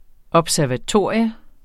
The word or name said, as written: observatorium